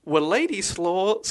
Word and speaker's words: Władysław: Her father, this is going to be very difficult to pronounce, but it's Władysław Sklodowski. – Władysław. – It is W-L-A-D-Y. S-L-A-W. Władysław.